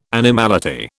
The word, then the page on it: animality: British English